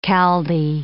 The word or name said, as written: Chaldee